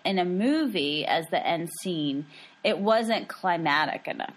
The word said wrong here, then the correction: climactic: Climatic? – Climactic. – Climactic. – You got to put some syllable in there.